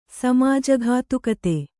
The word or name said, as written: ಸಮಾಜಘಾತುಕತೆ